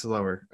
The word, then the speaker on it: Lauer: That's right. Spelled like lower. It's the only way somebody can correctly pronounce my name. It's lower. It's lower.